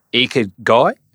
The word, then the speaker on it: ikigai: And is it the ikigai? How do I pronounce ikigai. – It? Ikigai. – Yes.